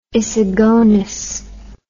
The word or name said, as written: issigonis